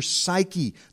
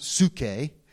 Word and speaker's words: psyche: But nevertheless, you have a soul, which is your psyche. The word there in the Greek is, it looks like psyche. It's actually pronounced psuche, if I want to be, you know, theologically, homiletically, hermeneutically correct. It is psuche, okay?